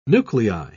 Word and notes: nuclei: British English

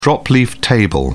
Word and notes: drop-leaf table: British English

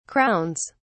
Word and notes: crowns: British English

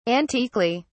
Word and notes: antiquely: British English

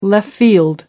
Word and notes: left field: American English

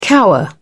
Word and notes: cower: British English

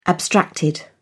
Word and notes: abstracted: British English

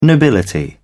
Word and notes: nobility: British English

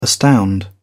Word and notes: astound: British English

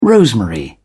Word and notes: rosemary: British English

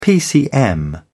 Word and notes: pcm: British English

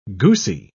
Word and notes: goosey: British English

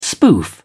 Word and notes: spoof: British English

